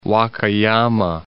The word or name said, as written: Wakayama